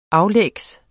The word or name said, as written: aflægs